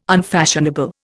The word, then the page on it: unfashionable: Indian